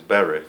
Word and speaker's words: berith: Well, the Hebrew word for covenant is berith. No, berith, okay. Berit. Yeah, okay.